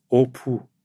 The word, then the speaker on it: Opu: Opu, das war meine Großmutter. Ja, mit U spricht man das aus. Opu.